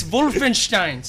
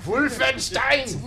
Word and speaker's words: Wolfenstein: It's Wolfenstein. Say it right. – Wolfenstein.